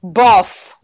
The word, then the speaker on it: LaBeouf: It's not LaBeouf. – What is it? – Just the F is pronounced. – So you're saying they say buff? – Yes.